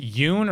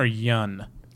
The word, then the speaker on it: Yeun: Is it Yoon or Yun? – I always pronounce it Yoon. – I say Yoon, too.